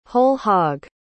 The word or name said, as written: whole-hog